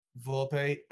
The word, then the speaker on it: Volpe: I think Volpe.